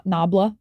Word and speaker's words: Nabla: I don't want to say it like that. Nabla. N-A-B-L-A. Nabla. It's an Italian brand.